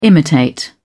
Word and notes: imitate: British English

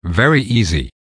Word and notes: very easy: American English

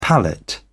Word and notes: palette: British English

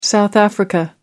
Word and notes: south africa: American English

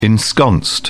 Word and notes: ensconced: British English

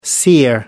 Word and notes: seer: American English

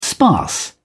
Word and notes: sparse: British English

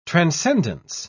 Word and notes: transcendence: British English

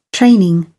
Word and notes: training: British English

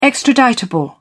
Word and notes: extraditable: British English

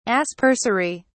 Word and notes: aspersory: British English

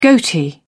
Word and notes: goatee: British English

